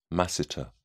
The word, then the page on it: masseter: British English